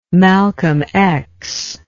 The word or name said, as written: Malcolm X